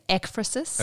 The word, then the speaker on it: ekphrasis: Or I think it's ekphrasis. – Okay, because I've been pronouncing it wrong then. – Well, it's not clear, but the accent's on the first syllable in Greek.